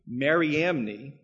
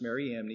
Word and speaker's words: Mariamne: He even had his wife, Mariamne, that's how her name is pronounced, Mariamne, he even had his wife, Mariamne, and three of his sons killed out of suspicion.